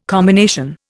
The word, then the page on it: combination: Indian